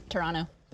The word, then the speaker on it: Toronto: Like leaving the other T off? – Yep. – Toronto? – Toronto. That's the way to say it.